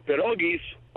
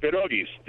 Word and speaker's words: pierogis: Anyway, and pierogis are pronounced pierogis. The R's are rolled, and it's not pierogi, okay.